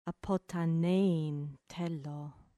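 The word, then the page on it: ἀποθανεῖν θέλω: ap ʌ t h an ɛ i ː n t h ɛ l ɔː; apothanein thélô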